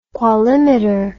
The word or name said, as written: qualimeter